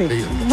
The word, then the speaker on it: Heyerdahl: Se pronuncia más fácil que cómo se escribe Heyerdahl. – Sí. – Heyerdahl.